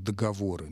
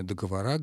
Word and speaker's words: договоры: Племен минувших договоры. Ну, сегодня договора, говорят бухгалтеры, но, тем не менее, основной вариант все-таки этот.